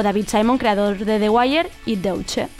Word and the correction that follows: The Deuce: A ver, no sé ni cómo se pronuncia. – The Deuce. The Deuce.